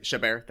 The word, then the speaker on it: Chabert: Chabert, thank you.